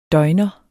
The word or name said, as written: døgner